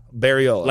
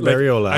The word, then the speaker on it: Bariola: Bariola. – Like Coca-Cola, Bariola. – Bariola. – Like Coca-Cola, Bariola.